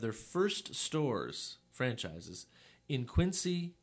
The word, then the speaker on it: Quincy: And Quincy is Quincy, is how you say it.